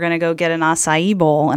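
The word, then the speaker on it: açaí: I like the name, and I thank you for helping me learn how to pronounce acai.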